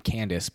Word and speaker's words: Candiace: C-A-N-D-I-A-C-E, Candy Ass. She's just pronounced her name Candace, but how can you not play around with that extra I?